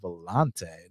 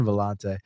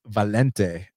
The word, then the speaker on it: Valente: His subsequent fight, when he fought that Brazilian, I think they pronounce his name Valente, not like my boy John Valente. It's Valente, Renato Valente.